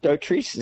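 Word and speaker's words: Dotrice: I think Dautrice is how we pronounce her name, who was his beleaguered wife, Betty, I don't remember.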